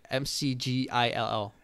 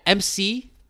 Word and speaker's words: McGill: Mac, McGill. – like McDonald's. – McDonald's, yes, my bro.